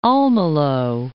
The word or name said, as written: Almelo